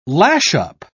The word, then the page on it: lash-up: British English